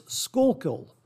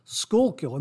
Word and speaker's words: Schuylkill: While editing this segment, I looked up the pronunciation of this name, and it's Schuylkill. Schuylkill.